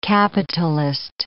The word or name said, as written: capitalist